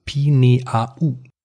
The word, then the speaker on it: Pineau: Äh, Pinot mit ja. der französischen Aussprache. Pinot. – Also Pinault. Äh, jein. Also Pineau. Pineau.